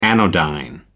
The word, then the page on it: anodyne: American English